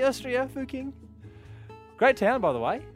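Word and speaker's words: Fucking: Fuking. – That's the official pronunciation.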